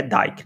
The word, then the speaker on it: Van Dyke: É, é Van Dyke ou Van Dyke que se pronuncia? Eu nunca sei, cara. Dyke. Dyke, né? Tá, porque eu já vi pessoas falando Dick e eu, pô, não é, é Dyke, né? Com Y.